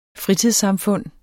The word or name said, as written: fritidssamfund